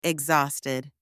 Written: exhausted

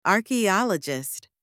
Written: archeologist

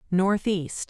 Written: Northeast

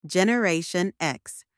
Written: Generation X

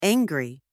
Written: angry

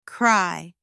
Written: cry

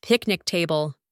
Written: picnic table

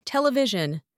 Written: television

